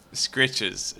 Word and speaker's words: scritches: He's like, wait a minute, I get free scratchies? – Scritches. It's pronounced scritches.